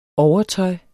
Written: overtøj

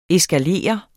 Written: eskalere

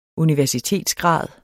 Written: universitetsgrad

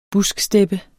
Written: busksteppe

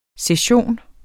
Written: session

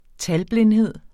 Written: talblindhed

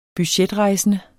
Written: budgetrejsende

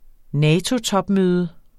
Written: NATO-topmøde

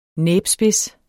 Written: næbspids

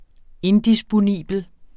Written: indisponibel

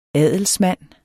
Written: adelsmand

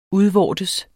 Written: udvortes

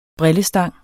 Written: brillestang

